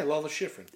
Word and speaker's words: Lalo Schifrin: Yeah, Lalo Schifrin.